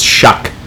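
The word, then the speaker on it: Shough: I thought it was Show. It's Shuck. So, there you go.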